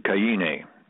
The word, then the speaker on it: Kaine: And if you want to put my name there, it's the last name is Kaine, K-A-I-N-E. In Hawaii, they pronounce it Kaine. Just kidding. – Uh-oh. – It's Kaine.